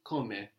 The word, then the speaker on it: come: So how by itself is come, with the accent on the beginning, come.